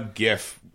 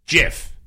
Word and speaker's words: GIF: I used a hard G. GIF.